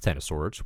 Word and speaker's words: X of Swords: So, what is the Ten of Swords? As it turns out, X of Swords is pronounced Ten of Swords, which is a reference to a tarot card.